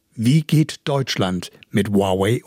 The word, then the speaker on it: Huawei: In der neuen Staffel geht es um den chinesischen Tech-Giganten Huawei, wie er eigentlich wirklich ausgesprochen ist, Huawei.